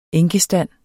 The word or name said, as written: enkestand